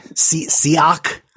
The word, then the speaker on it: Siach: Is it Sihik? I don't know how to say that. You know how to pronounce Hebrew. You're the real scholar here. Help me out. But Siach, I guess? – Yes.